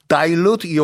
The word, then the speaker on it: dilute: Dilute your hand sanitizer, gel or spray가 왔는데 dilute라고도 발음해도 되고 dilute라고도 발음해도 되고 D-I-L-U-T-E가 이 희석하다라는 동사죠.